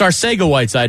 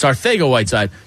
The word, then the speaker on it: Arcega-Whiteside: And it's a picture of Mike Tyson, and it's like, well, it's Arcega-Whiteside. It's like, no, the C is actually pronounced T-H. It's Arcega-Whiteside. Just say it like Mike Tyson would say it.